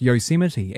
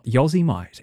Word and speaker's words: Yosemite: I was about 25 years old when I realized very publicly, I was actually talking on the radio, when I learned that the word Yosemite is pronounced Yosemite and not Yosemite.